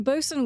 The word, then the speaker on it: boatswain: Well, spelled botswain. – Botswain. – But I think it's pronounced bosun. I don't know. This is a thing I'd find in a D&D book. Pretty sure they say bosun.